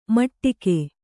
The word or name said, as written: ಮಟ್ಟಿಕೆ